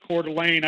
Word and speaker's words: Coeur d'Alene: But I have a buddy who moved recently to, I think it's called Coeur d'Alene, if I'm not mistaken. If I'm pronouncing it correctly. And I know that Coeur d'Alene, that's where I'm at.